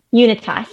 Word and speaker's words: UNITAS: She is also the director of survivor engagement at UNITAS. Am I saying that right? – UNITAS. – UNITAS.